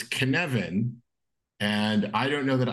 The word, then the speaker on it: Cynefin: It's this Cynefin, right? I'm saying that right. Right, Kate? – Yep. It's Cynefin. Yeah. – It's a weirdo Welsh word and it doesn't look like what it sounds like.